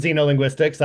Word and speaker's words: Xenolinguistics: I pronounce it Xenolinguistics. I don't, it's a new field. So, maybe it'll change as things go along.